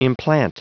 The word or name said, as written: implant